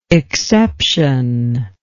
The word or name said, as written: exceptionless